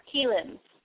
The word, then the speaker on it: Kirin: Well, it's Keelan, but it's spelled K I R I N because it's that weird, tricky R L sort of letter.